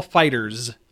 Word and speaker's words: FighterZ: It's Dragon Ball FighterZ. – Okay. – Yeah. that's the official.